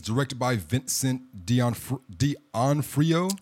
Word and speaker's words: D'Onofrio: Yeah, that's how it's spelled. You're pronouncing it right. Yeah, that's Vincent D'Onofrio.